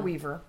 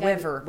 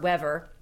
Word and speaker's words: Wever: And they are almost entirely being eclipsed by this young 20-something, Merritt Wever, I think is how you pronounce her name. – Or Weaver. – It doesn't have an A in it. – Oh, so Wever. – Wever,